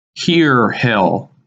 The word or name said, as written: Heer Hill